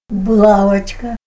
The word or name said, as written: bulawachka